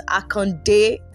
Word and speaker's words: Akande: So my email is Elizabeth Akande. The Akande is spelled A-K-A-N-D-E.